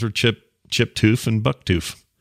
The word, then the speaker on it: Touf: He says, I knew of a family in the town I went to college in by the last name Toof, pronounced Toof, T-O-O-F, although pronounced T or spelled T-O-U-F.